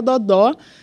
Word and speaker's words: Dodó: É, pode ser também o dodô, o dodô, o mas é, a, a, a, pronúncia é o dodó, a, ah, tem, tem o acento, né, é, é mais agudo no final.